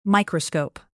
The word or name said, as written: microscope